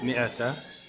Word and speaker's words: مئة: الألف في مئة هذا ليفرق بين منه. هذا من دقة الكتاب قديما، فيخافون أن كلمة مئة تحرف إلى من، فلذلك وضعوا هذا الألف. لكن هي مئة، الألف هذا لا ينطق.